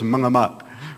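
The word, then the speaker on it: mengamuk: If you were in Indonesia, you would say it like this, mung amok, which means you have gone crazy.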